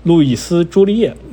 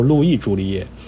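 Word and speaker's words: Louis Jolliet: 路易斯·朱丽叶应该，如果按照法语法语的发音，应该叫路易· 朱丽叶。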